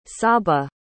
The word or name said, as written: saba